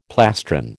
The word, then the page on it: plastron: American English